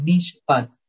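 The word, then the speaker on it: mishpat (מִשְׁפָּט): podemos decir que esa palabra justicia es mishpat. Así se pronunciaría en hebreo.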